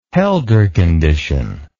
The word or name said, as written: holder condition